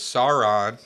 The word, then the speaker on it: Sauron: All right, so it's Sauron. It's Gimli. It's Boromir. It's Liv Tyler. I'm just all the mispronunciations you had there.